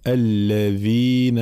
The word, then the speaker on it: الذين: نعطي الآن بعض الملاحظات الموجودة في تلاوة هاته الآيات في قوله تعالى إن الذين، الذال حرف مجهور، فلا نقول إن الذين في وكأنها ثاء، أو إن الذين وكأنها دال، لا، مجهورة ورخوة. الذال الذين، فلا بد من تطبيق هذه الصفات حال نطق الحروف. الذين هكذا.